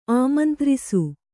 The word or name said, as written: ಆಮಂತ್ರಿಸು